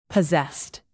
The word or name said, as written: possessed